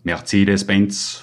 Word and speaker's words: Mercedes-Benz: Mercedes-Benz 500 SEC. Wobei die Betonung auf Benz liegt.